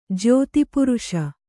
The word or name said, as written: ಜ್ಯೋತಿಪುರುಷ